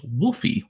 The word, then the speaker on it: Wolfi: So that's spelled W-O-L-F-I. It sounds lupine, but it actually refers to the smallest known species of octopus.